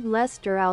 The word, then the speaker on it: Leicester: So, wie spricht man das aus? Los, komm, erzähl mal. Wie, wo ist das? Hier ist das. Los, erzähl mal. – Lord Leicester aus Manchester.